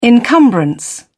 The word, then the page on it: encumbrance: British English